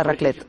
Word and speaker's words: raclette: Sí, sí, la raclette.